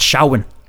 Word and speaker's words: Samhain: Yeah, we heard uh, Samhain. Actually, he's supposed to pronounce that Shawin.